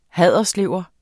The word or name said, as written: haderslever